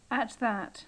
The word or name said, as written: at that